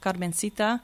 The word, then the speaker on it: Carmencita: And this is an actual historical dancer called Carmencita that I researched a bit. And she's the first woman ever to appear on film with Thomas Edison. – Yeah. – Pronouncing it the Spanish way.